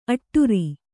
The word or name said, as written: ಅಟ್ಟುರಿ